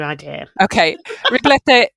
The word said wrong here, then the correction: ribollita: O-L-L-I-T-A. Riboletta.